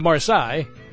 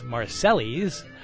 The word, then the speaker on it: Marseille: And all the way from France, the city of Marseille, which some people pronounce Marseilles,